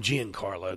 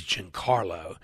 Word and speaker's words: Giancarlo: I always call him Giancarlo because I like the way it sounds. But evidently the proper way to pronounce his name, since he changed it, it's not even your damn name, is Giancarlo.